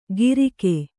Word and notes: ಗಿರಿಕೆ: Kannada

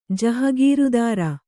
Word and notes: ಜಹಗೀರದಾರ: kannada; jahagīradāra

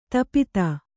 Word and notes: ತಾಪ್ತಿ: tāpti

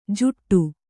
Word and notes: ಜುಟ್ಟು: juṭṭu

